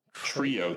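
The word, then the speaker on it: Treo: Trio, T-R, but it's spelled T-R-E-O, T-R-E-O.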